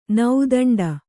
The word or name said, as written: ನೌದಂಡ